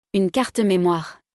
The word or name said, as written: Une carte memoire